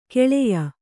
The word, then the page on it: ಕಾಳೇಯ: Kannada